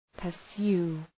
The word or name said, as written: pursue